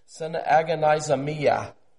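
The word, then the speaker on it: synagonizomai: It's translated actually from the Greek word, and I'm saying this phonetically because it's very hard. It's a long word, but it's synagonizomia. Synagonizomia.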